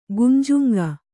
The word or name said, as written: ಗುಂಜುಂಗ